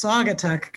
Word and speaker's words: Saugatuck: Is it Saugatuck? It's Saugatuck. – Good job.